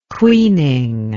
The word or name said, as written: queening